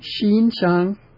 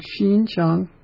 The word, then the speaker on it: Xinjiang: It's Xinjiang. That's the pronunciation of that region. Xinjiang. Two even-pitched. And the even-pitch vowel in Chinese is always higher. It's always a higher pitch, which is something I didn't know earlier in one of the earlier studies recently.